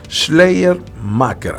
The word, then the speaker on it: Schleiermacher: Schleiermacher, ahí está, esa es la pronunciación correcta.